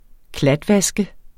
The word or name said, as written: klatvaske